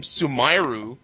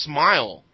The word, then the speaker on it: Sumairu: And then the thing is, the guy in the team that won, that's a Marduk player, everyone keeps calling him Sumairu because... – That's how it's spelled. – That's how it's spelled, but his name is Smile. Like, you know, half-face smile. But everyone calls him Sumairu because that's how they say smile in Japanese.